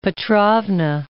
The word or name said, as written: Elizabeth Petrovna